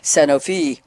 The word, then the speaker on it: Sanofi: We debated how to pronounce the company name, and he told me I didn't have to sound French, so I'm going to try Sanofi.